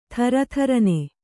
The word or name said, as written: ಥರಥರನೆ